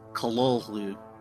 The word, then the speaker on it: Cthulhu: How did H.P. Lovecraft pronounce this word? Well, the short answer is Kololu.